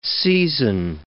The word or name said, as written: seisin